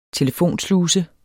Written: telefonsluse

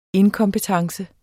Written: inkompetence

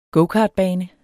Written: gokartbane

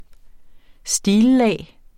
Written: stillag

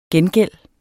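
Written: gengælds

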